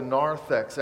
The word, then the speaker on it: narthex: I mean, if you come in from the back of the sanctuary, from the narthex, do you know that in the narthex, that's where the penitents worshiped God? In the old days, that room out there, it's called the narthex, N-A-R-T-H-E-X.